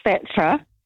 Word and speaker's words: Phaedra: That's actually pronounced Phaedra, and it's P-H-A-E-D-R-A.